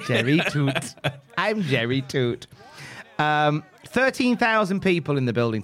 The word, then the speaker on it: Tuite: I never knew how it was pronounced, but it's like T-U-I-T-E. So you think it's like tweet. – Yeah, your instincts is tweet. – I hear it on podcasts. It's like, yeah, so Jerry Toot. And it's like, it's pronounced Toot. His surname is Toot.